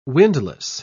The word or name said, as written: windlass